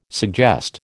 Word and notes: suggest: American English

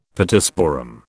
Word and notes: Pittosporum: British English

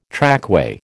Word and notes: trackway: American English

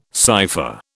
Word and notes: cipher: British English

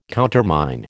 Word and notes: countermine: American English

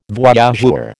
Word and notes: voyageur: American English

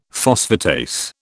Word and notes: phosphatase: British English

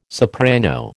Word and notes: soprano: American English

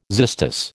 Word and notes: xystus: American English

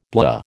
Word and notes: blah: American English

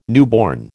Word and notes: newborn: American English

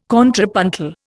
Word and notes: contrapuntal: Indian

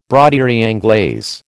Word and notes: broderie anglaise: American English

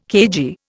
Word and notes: cagey: Indian

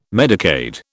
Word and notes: Medicaid: British English

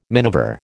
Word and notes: minever: American English

